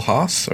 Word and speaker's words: Haase: Next one is from Bill Haas, or I guess that's how you pronounce it H A A S E.